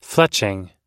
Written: fletching

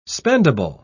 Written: spendable

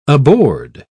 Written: aboard

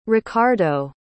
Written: Richardo

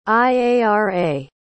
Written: iara

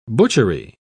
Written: butchery

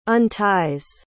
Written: unties

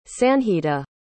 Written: sanjita

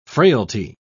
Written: frailty

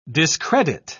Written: discredit

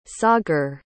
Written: sagar

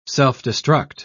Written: self-destruct

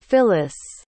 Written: Phillis

